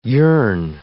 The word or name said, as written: yearn